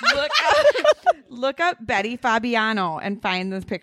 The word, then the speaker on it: Fabiano: Okay. Fabiano. You have to say it like that.